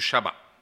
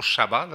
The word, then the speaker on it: Shabbat: » Du Shabbat. Je devrais dire prononcer toujours Shabbat, là, parce qu'en hébreu, c'est Shabbat.